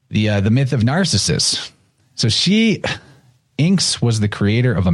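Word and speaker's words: Iynx: This is called an Inx. I think that's how you say it. It's I-Y-N-X.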